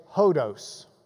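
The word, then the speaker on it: hodos (ὁδός): Your Greek word for the day is hodos.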